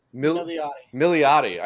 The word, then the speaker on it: Milioti: How do you pronounce her last name? – Milioti.